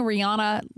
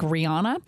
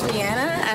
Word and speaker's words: Rihanna: If you've been pronouncing Rihanna like Rihanna, I guess you and I both are doing it wrong. She is in Paris, and here's how she pronounces her name. – Hello, British folk. It's Rihanna, and I want to welcome you to Paris, where I'm launching. – It's Rihanna. – Rihanna. So if you hear people say it that way, they're actually saying it right.